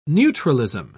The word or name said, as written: neutralism